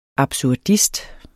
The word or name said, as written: absurdist